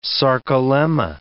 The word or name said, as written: sarcolemma